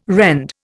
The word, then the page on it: rend: Indian